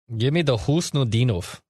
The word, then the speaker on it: Khusnutdinov: His name is spelled KHUSNUTDINOV. And I thought to myself, I got to find that pronunciation. I got no clue. According to Gord Miller, who calls the World Juniors for TSN, it's Husnudinov.